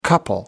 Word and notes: couple: American English

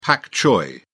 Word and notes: pak choi: British English

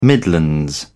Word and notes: midlands: British English

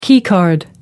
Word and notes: keycard: American English

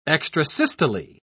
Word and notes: extrasystole: British English

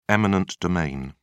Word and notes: eminent domain: British English

